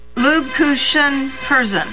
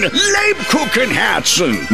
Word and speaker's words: Lebkuchen Herzen: Lubkuchen Herzen. – The Kupenhergens Herzen. – Lubkuchen Herzen. – Nine! Lebkuchen Herzen!